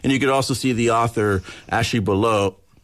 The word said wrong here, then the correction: Belote: Belote. – I'm doing a silent T, and it's not a silent T, reading the book as well.